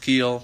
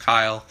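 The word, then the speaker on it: Keil: J-O-S-H-K-E-I-L. Not pronounced Keel, pronounced Kyle.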